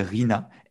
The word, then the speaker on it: RINA: Et alors en fait, c'est une question sur, c'est assez technique, assez spécifique, ça s'appelle RINA, R-I-N-A, comme ça se prononce.